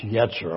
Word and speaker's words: yetzer: That word mind in the Old Testament is a Hebrew word, yetzer. It's pronounced yetzer.